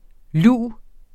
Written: luv

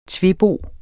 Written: tvebo

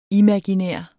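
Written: IMAGINÆRT

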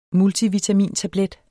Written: multivitamintablet

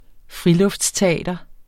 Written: friluftsteater

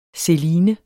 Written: seline

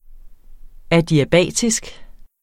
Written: adiabatisk

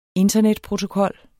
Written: internetprotokol

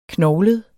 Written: knoglet

